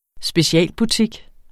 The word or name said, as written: specialbutik